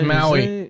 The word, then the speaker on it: Maui: He's Maui.